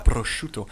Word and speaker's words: Prosciutto: Ich hätte voll Bock auf eine fucking Pizza Prosciutto gehabt. So, jetzt habe ich es richtig ausgesprochen. – Ja. – Prosciutto.